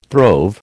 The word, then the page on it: throve: American English